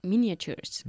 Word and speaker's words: miniatures: I've never shown you my collection of 16th century miniatures. – Miniatures. La A no se pronuncia. Miniature.